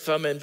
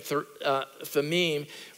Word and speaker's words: Thummim: Some of would say Urim and Thummim, but more accurately pronounced Urim and Thummim, which means the lights of revelation and the perfection.